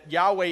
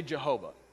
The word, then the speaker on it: Yahweh: Now this is where it gets confusing for us, because Hebrew didn't have vowels. And so the pronunciation of the word, the pronunciation of the name is unknown. I assure you that Yahweh is the best pronunciation. It's likely the best pronunciation. Some Bibles say Jehovah. That's fine too.